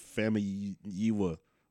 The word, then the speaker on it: Famuyiwa: Rick Famuyiwa.